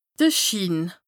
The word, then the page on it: de Schiin: Bas-Rhin; Strasbourg